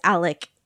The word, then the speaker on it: Alex: It was explained to us that that name is just pronounced as Alec in the South, even if it's Alex.